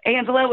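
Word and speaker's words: Angella: It's just my name, but my name is spelled goofy. It's Angela with two L's, and Sharp has an E at the end of it.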